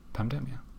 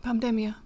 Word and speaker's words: Pandemia: Mario did also mention our friend Pandemia, who I would pronounce her name because her name is Pandemic, but in Spanish, but split into Pam. Demia. Yeah. And I would just pronounce it the same way. Pandemia, right? – Pandemia.